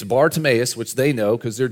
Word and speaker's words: Bartimaeus: Bartimaeus is the Hebrew pronunciation of a Greek name, okay? Bar means son of, and then Timaeus is the name. So he's the son of Timaeus, Bartimaeus.